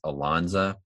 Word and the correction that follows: Alonzo: I yeah, I messed that up royally last podcast. – Yeah, so yeah i believe it's Alonzo Barnett.